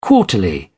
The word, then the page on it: quarterly: British English